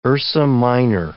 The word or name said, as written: Ursa Minor